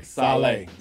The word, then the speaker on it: Saleh: But so if I'm a first-time, so the two coaches that everybody's hot after, Bieniemy and Sola. – Saleh. – Get his name right.